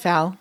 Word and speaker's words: Tefal: Tefal.